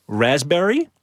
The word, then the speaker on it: raspberry: K-N-E-E-L, Neil. – Raspberry, answer. – Silent right. letters. – Yes, silent letters. Right. Raspberry is... – The silent S... – P. – Well, P. Sorry.